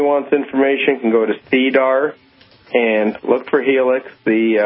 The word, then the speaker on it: SEDAR: In Canada, there is CDAR.